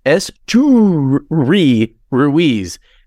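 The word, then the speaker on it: Esteury Ruiz: And so they've got the pronunciation guide in the media guide, and it says Esteyuri Ruiz.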